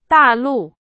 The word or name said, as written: 大陆